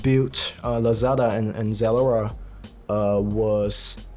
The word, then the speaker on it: Zalora: It's one of the largest e-commerce platform in Southeast Asia, and we know, uh, I don't know how to pronounce that, Z- Z- Zalora, right? – Zalora. Zalora. Zalora.